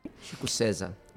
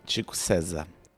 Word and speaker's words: Chico César: Chico César. – Chico César. – Qui, en près de 30 ans de carrière, est devenu une figure centrale de la musique populaire brésilienne. Et Chico César est aussi un artiste engagé.